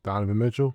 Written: Donovan Mitchell

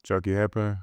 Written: Chucky Hepburn

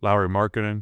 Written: Lauri Markkanen

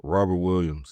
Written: Robert Williams III

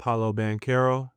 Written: paolo BANCHERO

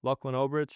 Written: Lachlan Olbrich

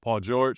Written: Paul George

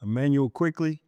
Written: Immanuel Quickley

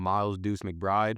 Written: Miles McBride

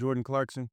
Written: Jordan Clarkson

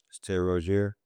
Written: erry ROZIER III

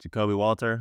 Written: Jakobe Walter

